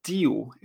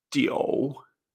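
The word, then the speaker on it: tio (九): Por ejemplo, el número 9, que lo tenemos aquí, en chino se dice J-I-U, en tercer tono. Y esto no se pronuncia tiu. Este es un error muy común ¿eh? de los que empiezan a estudiar chino. Esto se pronuncia tio en tercer tono, entonces, fíjate, no es iu, sino que es io, ¿eh?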